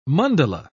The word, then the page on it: mandala: British English